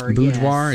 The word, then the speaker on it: boudoir: So earlier in the week, we were talking on the show about maybe an idea for Valentine's Day being a boudoir picture or something like that. – Boudoir, yes. – Is that how you say it? – Yeah, yes.